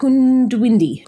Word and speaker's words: Goondiwindi: Did I say that right? Goondiwindi. – That's my understanding, yes.